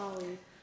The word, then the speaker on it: Raleigh: Yeah, Raleigh. So it's spelled R-A-L-E-I-G-H.